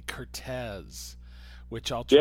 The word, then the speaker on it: Curtiz: But the correct pronunciation is Curtiz,